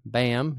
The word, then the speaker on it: Bahm: I am your host, Trey Bahm. My last name in print looks like it's pronounced anyway other than how I just said it, but that is my name, Bahm. You heard it correct.